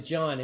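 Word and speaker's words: Jan: A lot of people, in English we pronounce it John, it's J-A-N, Huss, who was a Czech priest, and he started a movement in Bohemia based on the teachings of Wycliffe.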